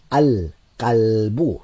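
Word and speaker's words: al qalbu (الكلب): al qalbu notice the lam al al uh, lam is being pronounced al qalbu is being pronounced with a sakin on it al qalbu the dog uh, the dog a word beginning with mim is ma'un.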